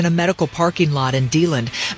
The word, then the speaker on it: DeLand: In Deland, Florida, is that how you say it? – Deland. – Deland, thank you.